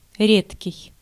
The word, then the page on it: редкий: /ˈrʲetkʲɪj/